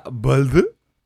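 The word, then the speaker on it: Välde: Se llama Balde. Eh, así se pronuncia, lo busqué en Google.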